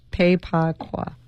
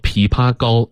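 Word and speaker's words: 枇杷膏: So it goes Pei Pa Kwa. – Pei Pa Kwa. It's actually called Pi Pa Go in Chinese.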